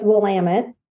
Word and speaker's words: Willamette: So it's Willamette. – Oh, Willamette. Okay. – And settlers were calling it the Willamette River. So we tell our tourists that to help remember them how to pronounce it, we say Willamette, damn it.